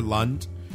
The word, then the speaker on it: Lunt: Lunt rhymes with cunt.